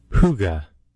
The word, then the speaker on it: Hygge: I have the pronunciation of that word if you would like. – Okay. – Hygge. – Hygge? – Hygge. – Hygge, okay.